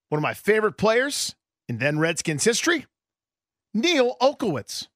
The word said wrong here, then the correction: Olkowitz: You're not saying it right. – Neil Okowitz. – Okowitz. – Oh, my God. Neil, if we can't even get him to say it right, how's he going to spell it? Olkowitz. – Olkowitz. – There it is. – N-E-I-L.